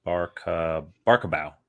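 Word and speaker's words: Barbakow: But the latter project adding Fraser to its cast is a comedy from Palm Springs director Barbakov. Barkabow. I'm sorry, I've been mispronouncing that.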